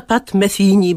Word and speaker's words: Pat Metheny: Zaczyna Pat Metheny, bo tak się tego Pata Metheny'ego powinno wymawiać,